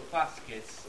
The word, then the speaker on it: fasces: In that case, it would be it would be fas. The C is always hard, so fasces.